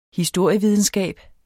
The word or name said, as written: historievidenskab